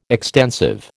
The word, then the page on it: extensive: American English